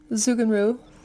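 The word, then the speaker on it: Zugunruhe: How do you pronounce that word? – Zugenruh. That's how I pronounce it.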